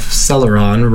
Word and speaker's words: Celeron: Celeron is 7 letters and 3 syllables like Pentium. The cell of Celeron rhymes with tell of Intel.